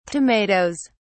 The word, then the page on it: tomato(es): British English